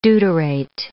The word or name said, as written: deuterate